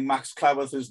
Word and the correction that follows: Cleworth: name wrong all the way through since he broke into the team. And so it's from now on, it's Max Cleworth, and that's straight from his dad.